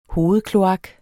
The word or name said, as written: hovedkloak